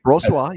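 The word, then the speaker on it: Brossoit: I agree that Brossois, or however you pronounce his name. – That's right, Brossois. You got it.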